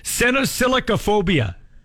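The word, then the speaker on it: cenosillicaphobia: It's called senosilicophobia. – Ah. – Yeah. It's a big long thing, but that's how you pronounce it.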